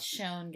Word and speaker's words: Chão: Fogo de Chão is how most people say it wrong. But the Brazilian steakhouse. – Chão, guys.